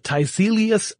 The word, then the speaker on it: Tiselius: Perkin Elmer introduces the Tiselius electrophoresis instrument.